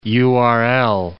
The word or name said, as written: URL